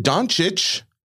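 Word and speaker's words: Dončić: I think that the biggest implication to the card market world is that how do you say it? – Doncic? – Is it Doncic? – I believe so.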